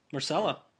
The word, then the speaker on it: Myrcella: Myrcella.